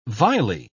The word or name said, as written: vilely